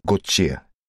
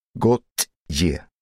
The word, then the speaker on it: Gauthier: Jag heter Gauthier. Uttalas Gauthier. Gauthier. Inte spooky.